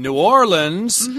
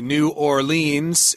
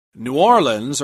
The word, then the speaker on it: New Orleans: Right, and we should also mention that Mardi Gras takes place in New Orleans, which is the correct pronunciation. A lot of people will say New Orleans.